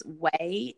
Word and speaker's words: OUAI: It's Way, and it's spelled O-U-A-I.